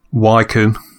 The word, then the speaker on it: Wycombe: If you, it's pronounced Wycombe. – It is? Okay, my bad.